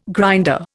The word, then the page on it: grinder: Indian